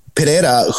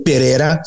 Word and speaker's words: Pereira: Against the guy in Pereira who beat him as a kickboxer. – I like the way you pronounced that was good. – Pereira.